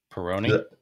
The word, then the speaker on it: Peroni: I want to say Peroni. Am I saying that right? – Peroni.